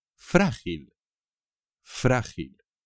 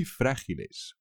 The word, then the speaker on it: frágil: Frágil, frágil. Cuidado con los cristales. Son muy frágiles. O sea que ojo, ojo, ojo. Frágil. También con un acento por ahí, ¿eh? Cuidado.